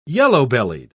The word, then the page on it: yellow-bellied: English; British English